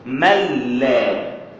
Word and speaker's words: ملا: وذكر الإمام الجليل ملا علي القاري، بعض الناس يقول ملا علي القاري، بعضهم ينطقها ملا علي القاري، وأصل الكلمة ملا أو ملا أصلها من كلمتين: من لا يجهل، أي العالم، من لا يجهل أي العالم، فأضغمت هذه في تلك واختصرت فصارت ملا، فهذا هو النطق الأصوب لها، ملا أي من لا يجهل أي العالم.